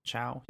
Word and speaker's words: Chao: And a Chao. Is that how you pronounce that? – I think so. – Chao, yeah.